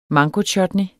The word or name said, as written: mangochutney